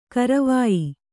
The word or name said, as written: ಗರವೊಯ್